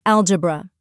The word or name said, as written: algebra